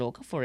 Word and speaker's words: Okafor: Ocafort. – Okafor. – Muy bien, muy bien la pronunciación.